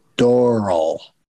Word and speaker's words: Doral: The improv in someone recently told me, I always call it Doral, but somebody recently told me that it rhymes with coral. So it's more like Doral.